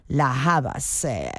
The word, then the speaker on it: La Havas: Y bueno, veo que es una adaptación fonética del apellido griego del padre de esta artista británica. Bueno, pues nada, no sabía cómo pronunciarlo, lo he ido a comprobar, y sí, es Lian La Havas, quien bueno, pues nos ofrece hoy esta versión del tema original de Radiohead.